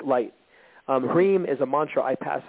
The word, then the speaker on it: Hrim: The mantra Hrim, H-R-I-M, but the I spends like long E, so it's like Hrim, Hrim.